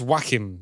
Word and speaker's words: Joachim: Prince Joachim, yes. Well, actually, it's spelt J-O-A-C-H-I-M, but he is very much a German.